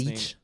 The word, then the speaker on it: Leitch: David Leitch, L-E-I-T-C-H, I'm probably butchering his last Leitch.